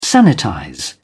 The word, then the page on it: sanitize: British English